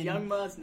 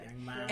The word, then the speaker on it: Young M.A: Young Ma. It's M.A. That's how she pronounces it. – There's no dots, though. – I know, but she says Young M.A.